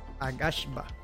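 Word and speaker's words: Aghasba: Agashba, así se pronuncia este juego se describe como un juego de construcción de acción y aventura eh, ambientado en un mundo de fantasía único.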